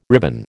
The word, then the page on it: ribbon: American English